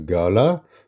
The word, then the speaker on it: gala: Gala, notice how we have to pronounce gala in the Indian accent as gala, so it rhymes with Madhushala.